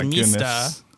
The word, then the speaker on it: Mista: No, you actually said it wrong. It's Mista! – Mista! – Sorry, go ahead. I didn't mean to annoy everyone in the audience, but that's how you say it.